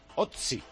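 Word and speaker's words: Ozzy: Es el director de la película Otzi. ¿Se pronuncia así? O-Z-Z-I. – Efectivamente, sí.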